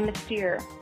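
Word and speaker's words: Mystère: Ooh, we stayed at the Aria and saw Mystery. Is that how you say it? – Mystere.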